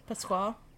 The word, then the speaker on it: Pasquale: The next guy, his name is Frank Pasquale. I think that's how you say it. P-A-S-Q-U-A. Pasquale. – Pasquale, yeah.